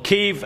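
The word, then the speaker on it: Kyiv: Seesaw in Kiev, or Kiev as it's pronounced now.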